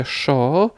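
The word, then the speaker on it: yo: Y, tam literę y oraz podwójne l wymawia się jako szy. Tak więc ja wymawia się szo, a ulice y, w argentyńskim hiszpańskim jako kasze.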